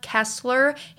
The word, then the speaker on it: Kesler: Um, as always, you can follow my vlog channel on YouTube if you just type in my name, Hannah Kesler.